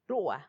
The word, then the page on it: rua: Macuiltianguis